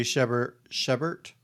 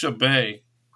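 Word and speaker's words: Chabert: And this feature film was the debut of Lacey Shepert. Is that how you pronounce her name? – I think it's Shebae.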